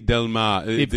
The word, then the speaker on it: Delmas: If you haven't looked at it, Delmas is spelled D-E-L-M-A-S. Lewis is L-O-U-I-S. – Louis Delmas. The S is, if everyone knows who speaks French, the S's are silent in French.